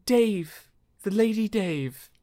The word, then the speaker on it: Daeve: It's spelled funny. It's like D-A-E V E.